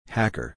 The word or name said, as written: Hacker